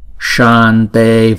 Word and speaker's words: shante: Shante varade. Chữ sa, chữ sa này chúng ta đọc hai nhịp rồi chúng ta mới đóng chữ sa đó lại là shante shante.